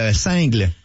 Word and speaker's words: single: Bon, ben, nous autres, là, on fait le festival de Saint-Tite, puis on a une pub pour nos trois nouveaux singles. – Non, ça se prononce comme le fromage, single.